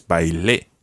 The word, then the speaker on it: bailé: Yeah, just watch your stress, bailé. – Bailé con mi amigo. – And that stress is really important, particularly in this preterite tense, because there really is a stress on the E. Canté, hablé, bailé.